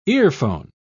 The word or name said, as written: earphone